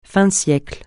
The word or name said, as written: fin de siècle